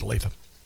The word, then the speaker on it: Olathe: Yeah, Olathe. It's wonderful. It's actually pronounced Olathe.